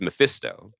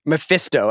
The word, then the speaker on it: Mephisto: That was sort of a Ferrari-esque, where the I becomes an E. – Yeah. – I've always said it as Mephisto. – Mephisto.